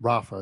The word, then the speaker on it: Rafah: And this area, Jonathan, is it Rafah, is how you pronounce it, correct? – That's correct.